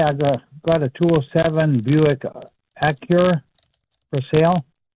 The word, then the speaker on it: Acura: No, Acura. A-C-U-R-A. – A-C-U-R-A.